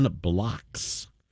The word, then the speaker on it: Blockx: And music of the Flemish composer Jan Bloks. His name is spelled B-L-O-C-K-X. That's kind of cool, isn't it? Rhymes with socks.